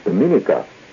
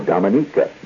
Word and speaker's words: Dominica: Just about 20 miles away is the island of Dominica, or Dominica, depending on how you wish to pronounce it. It's pronounced both ways on the island, incidentally.